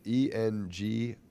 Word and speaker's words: Engerix: The Ingerix? – Yeah, I think that's how you pronounce it. I'm not bad with pronunciations. – Let's see here. So I'm always saying that because I want to know Ingerix. Engerix. – Yeah, I think that's it.